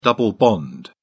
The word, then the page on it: double bond: British English